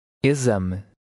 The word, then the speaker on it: Exame: In numbers 8 through 10, the X is pronounced like a Z. Try to read number 8. – Exame.